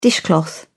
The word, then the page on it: dishcloth: British English